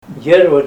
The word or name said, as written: deriwa